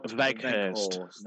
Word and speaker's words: Weghorst: This one is for Weghurst. Is that how that's pronounced? Weghorst? – Wout Weghurst. – There you go.